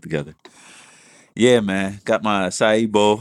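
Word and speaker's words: açaí: Got the little, how you say it? Acai bowl? – Acai.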